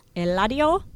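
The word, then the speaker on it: Eladio Carrión: Eladio Carrion. – Eladio. Eladio. – Eladio.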